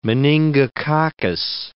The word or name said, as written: meningococcus